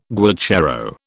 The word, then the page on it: guacharo: British English